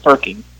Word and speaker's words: Furking: Yeah, the first R is silent. Furking.